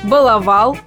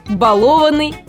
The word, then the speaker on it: баловать: Итак, ни в одной форме и ни в одном однокоренном слове ударение не падает на первый слог. Поэтому правильными будут ударения «баловал», «балованный» и так далее. Вообще, чтобы было удобнее запомнить, зарифмуйте два слова «баловать» и «целовать».